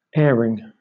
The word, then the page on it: airing: British English